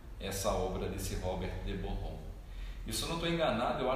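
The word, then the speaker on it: Boron: esse Borron com um R só apesar de se pronunciar como Rom, né, mas é um R só. Robert de Borrom.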